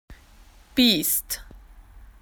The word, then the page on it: wild animal: Australian English